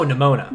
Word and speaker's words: Nimona: Nimona, is that what it was? – Oh, Nimona.